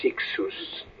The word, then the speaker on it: Cixous: Ça s'écrit avec un C au début. Ça se termine par un S, ça se prononce Cixous à l'occasion.